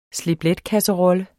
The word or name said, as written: slip